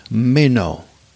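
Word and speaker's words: meno (μένω): The original Greek word used here is pronounced meno, and means to actively stay and be continually present in a place and not depart.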